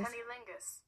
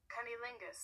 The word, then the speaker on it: cunnilingus: Cunnilingus. – What? – Cunnilingus.